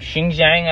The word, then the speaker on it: Xinjiang: We know there is an incredible amount of misinformation surrounding things like Xinjiang, Xinjiang, I believe it's actually pronounced, surrounding things like, you know, the communists' party's history throughout China.